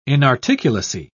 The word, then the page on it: inarticulacy: British English